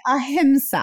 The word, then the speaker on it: Ahimsa: Is that, am I pronouncing Ahimsa correctly? – It's Ahimsa. Like, I knew it.